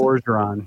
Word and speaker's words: Orgeron: Ed Orgeron? Is that it? Is that how you pronounce it? – Ed Orgeron.